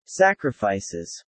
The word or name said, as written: sacrifices